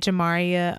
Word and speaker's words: Jamaria: jamaria olivia j my name is spelled J A M A R I A, Olivia J.